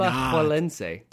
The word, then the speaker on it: Alajuelense: No, the J is a ch noise. – We're not going to do that. – Alajuelense.